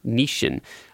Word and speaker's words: Nissin: Nissin.